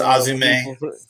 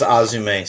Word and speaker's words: AZM: And it's Azume, sir. She got chastised for pronouncing it that way in messages. That it is the letter spelled out AZM, but it's pronounced Azume, sir.